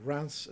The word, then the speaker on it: Reims: Reims or Reims, as it's pronounced,